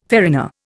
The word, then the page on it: farina: Indian